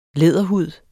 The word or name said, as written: læderhud